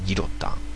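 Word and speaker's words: Guillotin: で、 685、フランス語では愛の発音はアになるからね、ギロタン、ギロティーン、ギロティーンで、ギロタン。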